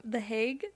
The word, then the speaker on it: The Hague: The Hague? – Oh, yeah.